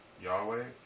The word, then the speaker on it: Yahweh: Or you can pronounce it Yahweh, or you can pronounce it Jehovah. Okay. Those are the three.